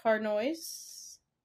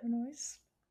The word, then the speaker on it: Harnois: So next month we are reading Rainbow Islands by Devin Harnois. – I think that's how you say it, Harnois. – So Harnois.